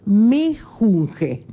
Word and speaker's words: mejunje: no se dice menjunje, es Ah. – mejunje.